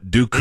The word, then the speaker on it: Doku: Not Doku and...